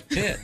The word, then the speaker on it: Pitt: Train with Brad Pite stays at number one. – That's Pitt. – I got to go see this one. I really like the older Brad Pitt now.